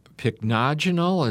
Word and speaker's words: pycnogenol: The pycnogenol, is that how that is pronounced? – That is.